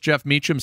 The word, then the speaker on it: Jeff Meacham: I'm your host, Jeff Meacham, spelled with a J, not that stupid G way of spelling it.